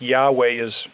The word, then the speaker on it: Yahweh: And so they would not pronounce the name, but we believe most of the scholars who have taken a look at the tetragram and how it is pronounced, they probably would say that Yahweh is as close as any in terms of pronouncing it.